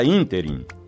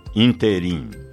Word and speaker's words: ínterim: A palavra ínterim é proparoxítona e, portanto, com acento gráfico na antepenúltima sílaba, e não oxítona, como ouvimos por aí, interim. Interim não existe.